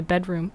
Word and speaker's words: bdrmm: I believe that's Bedroom, but it's just spelled without the vowels. So this is The Noose by Bedroom.